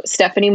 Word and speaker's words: Steffanie: So Steffanie Moyers, and Steffanie is spelled Steffanie.